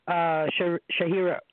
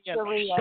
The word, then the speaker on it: Sharia: So, you know, that And the issue is, if I understand what we were discussing, how Shahira, I can't say it properly. – Sharia.